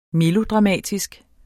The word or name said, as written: melodramatisk